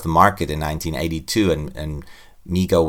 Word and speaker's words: Mego: you'd see that the toy company Mego, or you pronounce it Mego, right? – Mego.